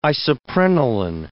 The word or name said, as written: isoprenaline